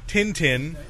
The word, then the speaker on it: Tintin: They kept pronouncing it. 10-10. I was like, where do you get 10-10 out of that?